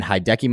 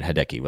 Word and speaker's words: Hideki: Hideki. I'm going to say Hideki now. – No, you're saying it wrong. It's disrespectful to Masters champion. – It is. No, you're right. Hideki, what's up? – Hideki, man.